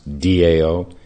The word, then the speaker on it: Tao: It's an English translation of the word Tao, T-A-O, pronounced D-A-O.